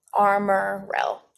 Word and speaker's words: Armorel: I mean, is there different pronunciations on how you pronounce that place, or what's the right pronunciation? – We've always called it Armorell. Armorell.